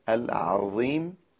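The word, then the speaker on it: العظيم: در نماز هم ما کلمه ای داریم که حرف ضا درش هست و اون کلمه العظیم در عبارت سبحان ربی العظیم و بحمده، اگر دقت نکنیم یا عظیم میشه که اشتباس یا عظیم میشه که با حرف ضال مخلوط میشه.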